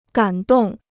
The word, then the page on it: 感动: gǎn dòng